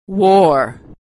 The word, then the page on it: war: American English